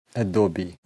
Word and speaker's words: Adobe: долгие годы было распространено чтение Adobe, потому что все думали, что э, конечное «е» не читается, и вообще там нет никакого «э». Ну, а потом пришел Дима и всем выступил с докладом, и все узнали, что это Adobe.